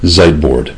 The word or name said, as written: ziteboard